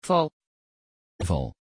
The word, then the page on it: val: Dutch